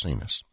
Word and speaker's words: Sines: When you get into Romance languages, while they may look alike, they are pronounced differently. In Portugal, it's Sines.